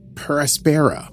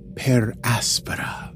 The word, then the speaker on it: per Aspera: They pronounce it Paraspera, which I think is terrible. AdAstra per Aspera is how I would say it.